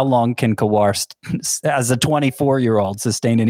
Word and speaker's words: Kowar: It is indeed Kowar, at least the video that I pulled up here. So that is how you pronounce his name, and I agree.